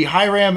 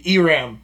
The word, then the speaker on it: Hiram: So Hiram is, in English would be Hiram. In a Spanish accent, it would be Iram. Okay. So I'm going to go with hi most of the time.